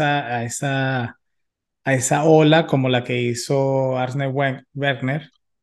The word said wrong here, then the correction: Arsène Wenger: Werner, eh, nunca sé pronunciar su nombre. ¿Cómo lo pronuncias tú? Arsène Wenger.